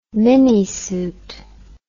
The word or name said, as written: minisuit